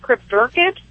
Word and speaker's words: cryptorchid: He was, am I saying it right, Cryptorchid? – Oh, yeah.